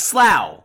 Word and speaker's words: Slough: Was, it's Slough.